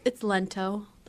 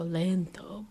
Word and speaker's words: Lento: It's Lento, like the musical term. I guess you could put a little flair on it and go Lento, but Lento.